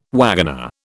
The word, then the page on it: wagoner: British English